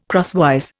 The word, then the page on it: crosswise: Indian